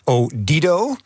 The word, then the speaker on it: Odido: dat is de naamswisseling van T-Mobile in ehm, Odido. – Goede klemtoon. ja, goede ja. klemtoon, hè, want ik heb mijn research gedaan.